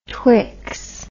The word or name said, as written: twx